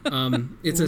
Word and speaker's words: Ouya: It's Ouya.